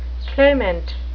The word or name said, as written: clamant